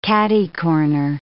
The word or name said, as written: catercorner